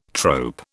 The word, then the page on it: trope: British English